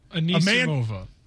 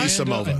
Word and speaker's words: Anisimova: Anisimova. – How do you say Anisimova. – It? How'd you say it? – Anisimova.